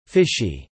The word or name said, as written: fishy